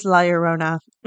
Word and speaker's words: La Llorona: I'm back. It is La Llorona.